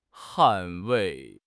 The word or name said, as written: 捍卫